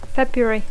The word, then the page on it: February: English